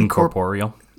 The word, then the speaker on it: incorporeal: with incorp. Oh my god, I can barely pronounce this word. Help me out here. – Incorp- Incorporeal.